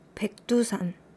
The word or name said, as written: baekdusan